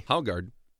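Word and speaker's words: Haugaard: as Republican Speaker of the House Steve Haugard... – That's how you pronounce it. Very good. – Haugard, really? – Haugard.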